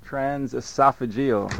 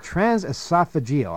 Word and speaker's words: transesophageal: Transesophageal. – That's the word. Transesophageal.